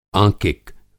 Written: आँकिक

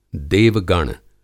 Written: देव-गण

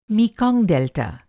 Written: मीकांग

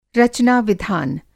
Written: रचना-विधान

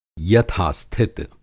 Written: यथा-स्थित

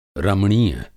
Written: रमणीय

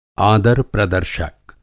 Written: आदार-प्रदर्शक

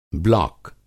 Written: ब्लाक

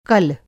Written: कल

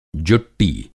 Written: जुट्टी

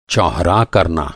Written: चौहरा

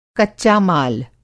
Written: कच्चा-माल

